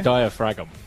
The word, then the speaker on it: diaphragm: It's spelled diaphragm. It's got the G in there.